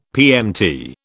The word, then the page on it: PMT: British English